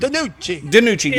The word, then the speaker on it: DiNucci: How do you say his last name? – DiNucci. – Yes. – DiNucci.